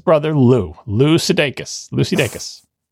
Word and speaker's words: Dacus: I can affirmatively say that he pronounces his name day like the unit of time and cuss like you were saying a swear word. So Lucy Dacus.